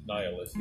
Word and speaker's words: Nihilist: The Nihilist? – Nihilist, yeah. – Nihilist? Okay, cool. So I did say it right.